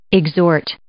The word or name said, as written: exhort